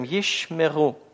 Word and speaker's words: ешмеру: Первый будет всегда немой, то есть он будет закрывать предыдущий слог. Ищ не произносится после ше ничего, да? А следующий, естественно, будет открывать слог. Ему ничего другого не остается, поэтому он всегда будет произносимый. И мы говорим «ешмеру», «ешмеру». Вопрос?